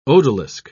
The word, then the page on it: odalisque: British English